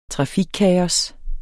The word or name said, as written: trafikkaos